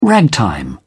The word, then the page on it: ragtime: British English